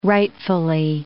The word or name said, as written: rightfully